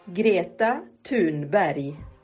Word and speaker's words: Greta Thunberg: Greta Thunberg. – Greta Thunberg. – Greta Thunberg. – Greta Thunberg. – Greta como Greta, lo que tienen que arreglar los fontaneros, sí, sí, en casa. Greta Thunberg, pero así como interesante.